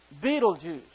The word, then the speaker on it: Betelgeuse: This is pronounced Betelgeuse.